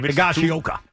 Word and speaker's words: Higashioka: Higashioka.